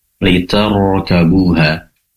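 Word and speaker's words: Liter Gabuha (لتركبوها): Yang ingin kami sampaikan ketika membaca Liter Gabuha, di sini roknya tebal karena rok sukun didahului oleh fathah. Maka cara penabalannya dengan mengangkat pangkal lidah ke atas Liter Gabuha.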